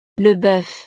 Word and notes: le boeuf: frances

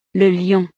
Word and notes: le lion: frances

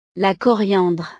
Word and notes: la coriandre: frances

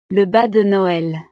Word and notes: le bas de noel: frances

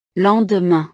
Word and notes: le lendemain: frances

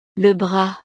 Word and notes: le bras: frances

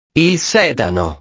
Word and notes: il sedano: italiano

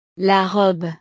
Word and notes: la robe: frances

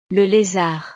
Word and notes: le lezard: frances

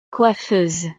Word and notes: coiffeuse: frances

